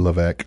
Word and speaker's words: Levesque: Levesque.